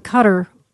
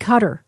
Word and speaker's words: Qatar: And so one wouldn't think that they would be like working with Qatar is the way I understand it's pronounced.